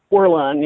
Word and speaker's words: Werlein: did I say his name right? – Werlein, yes.